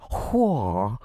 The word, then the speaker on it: HOA: Hwa. Spelled H-O-A, but pronounced Hwa.